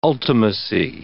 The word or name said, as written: ultimacy